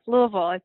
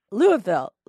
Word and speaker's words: Louisville: Close enough. Louisville. It's one syllable as you can get. Yeah. – Louisville.